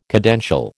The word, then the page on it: cadential: American English